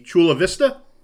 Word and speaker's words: Chula Vista: And you grew up in, if I'm pronouncing it correctly, Chula Vista? – Yes, Chula Vista, California.